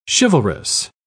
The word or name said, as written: chivalrous